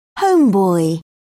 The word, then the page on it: homeboy: British English